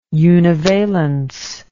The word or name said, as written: univalence